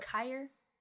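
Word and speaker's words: Keir: I think that I'm pronouncing that right. Kier? – That sounds right. – Kier? Yeah. He goes and shows the ropes to Vec, but Blart keeps getting distracted by the new kiosk worker, Amy, played by Jema.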